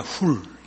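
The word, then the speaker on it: 훌 (חור): 벤허는 영어 발음으로 허가 됐지 H-U-R로 표기된 허는 훌입니다. 훌. 히브리어로 훌이고 훌은 여러분 잘 아시는 출애굽 때 모세를 양쪽 팔을 잡아준 아론과 또 한 사람 훌입니다.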